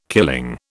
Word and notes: killing: British English

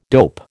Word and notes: dope: American English

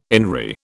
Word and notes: INRI: British English

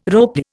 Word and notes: ropy: Indian

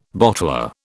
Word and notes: bottler: British English

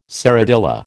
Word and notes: serradilla: American English

